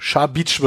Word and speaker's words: Šabić: Mein Name wird ja auch mal gern falsch ausgesprochen. Deswegen also Shabitsch wird's gesprochen.